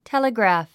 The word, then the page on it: telegraph: téləgræ̀f; テレグラフ